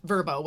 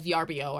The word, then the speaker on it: Vrbo: I always used Vrbo, which I always called VRBO. I just found out recently, not how you pronounce it.